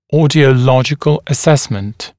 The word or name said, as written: audiological assessment